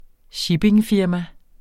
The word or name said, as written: shippingfirma